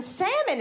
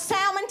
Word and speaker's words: salmon: My family thinks I'm uppity because I know the L in salmon is silent. They gonna call it salmon till they die.